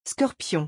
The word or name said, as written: scorpion